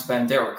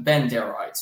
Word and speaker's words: Bandera: Although, interestingly, he can't even pronounce Bandera correctly. He keeps referring to these Banderaites, he calls them Benderaites.